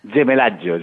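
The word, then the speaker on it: Gemellaggio: que segurament ara pronunciaré malament, però que es titula Gemeliaggio, patrimonis agermanats. – Gemelaggio, que en italià vol dir agermanament,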